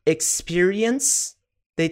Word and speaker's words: experienced: Experience, daí tem o ED no final, fica experienced. É só um T no final, não é experienced, não, Não é como ler, é experienced.